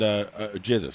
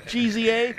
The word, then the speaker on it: GZA: And GZA, excuse me. – Thank you. – Yeah. – GZA, thanks. one of my favorite rappers, and I just mispronounced his name. – GZA.